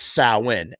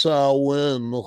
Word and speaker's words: Samhain: So the combination of wanting to celebrate and also ward off spirits birthed a festival known as Samhain, but it's pronounced Samhain.